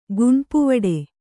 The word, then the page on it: ಗುಣ್ಪುವಡೆ: kannada